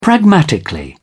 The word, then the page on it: pragmatically: British English